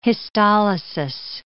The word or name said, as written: histolysis